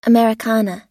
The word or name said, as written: americana